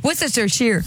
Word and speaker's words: Worcestershire: Là, là-dedans, tu vas mettre la Worcestershire. Le Worc... Non, la Worcestershire. – La Worcestershire? La Worcestershire. – Après, je n'ai dit un trop. Worcestershire. Worcestershire. – OK. – La Worcestershire? – Ah, c'est ça! Worcestershire.